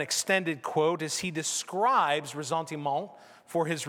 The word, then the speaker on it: ressentiment: and I'm going to butcher the French pronunciation, but it is known as ressentiment. Ressentiment. I checked with Amy in between, who's studied French. She said I was close, so that's good.